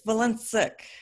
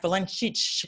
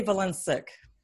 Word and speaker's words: Valencic: Really talking Eastern European, it'd be something like Valencic. – Valencic, right. Yeah. Valencic. – Yeah, I say Valencic.